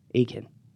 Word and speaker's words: Atkin: And I was like, no, you don't pronounce the T. He's like, oh, Atkin. I'm like, Akin. Akin.